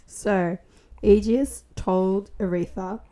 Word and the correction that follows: Aethra: Not Aretha. Aethra. – Sorry, it's spelled with an A. – Aethra? – It's pronounced Aethra.